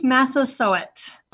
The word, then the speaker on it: Massasoit: Yes, Chief Massasoit. For the grandparents and parents and kids that are listening, just say sow it, like sow it up. It's Massasoit.